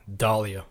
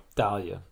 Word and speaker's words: Dahlia: They say that Alyssa's mom, how do you say her name again? – Dahlia. – Dahlia, excuse me.